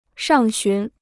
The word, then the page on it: 上旬: shàng xún